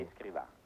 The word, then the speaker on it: Escrivá: Escrivá, sì. – De Balaguer. – De Balaguer, sì. – De Balaguer. – Ma può essere sufficiente Escrivá.